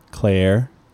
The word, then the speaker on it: Cleyer: But this guy, Andres Kleier, or Clura, how would you pronounce his last name? Can you see it? It's spelled C-L-E-Y-E-R. – Claire.